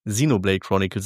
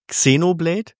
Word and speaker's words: Xenoblade Chronicles: Ich habe ja, haben auch Leute gesagt, so Xenoblade Chronicles 2 DLC. Ja, ich habe gerade Xenoblade gesagt. Für die Schlauberger hatte ich neulich in der Story-Diskussion. Xenoblade Chronicles sage ich. Nicht Xenoblade, auch nicht Xenoblade.